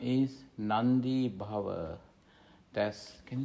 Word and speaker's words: nandi bhava: That's N-A-N-D-I. It's pronounced longer. Nandi. In fact, it's one word, but however, I just written it as two different words. Nandi, and then B-B-H-A-V-A, Bhava. Nandi Bhava. This is a compound, actually. Compound. Grammatically speaking, this is a compound. Nandi Bhava. N-A-N-D-I, pronounced longer. So, Nandi, with the diacritic mark. So, Nandi, and B-H-A-V-A, Bhava.